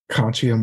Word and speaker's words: Conscio: So we're also here to talk about Conscio Marketing. Did I say that correctly? – You did. Yeah. – Okay, good.